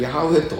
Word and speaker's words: ヤハウェ: 本来ヤハウェと発音すべきなのただエホバになっている、まあ、まあこれ間違いなんだけども、まあ、間違った発音でもそれはまあそれでいいです。